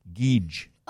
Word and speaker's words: Geedge: Wait, is Geege spelled like G-E-E-G-E? – It is. Yes, it is. Actually, G-E-E-D-G-E. Geege. – Oh, Geege. Part silent D, I guess.